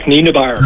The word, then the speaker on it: Nienaber: And that's, I can't pronounce his name, is it Nabar? – Jack Nienaber. – Nienaber, yeah.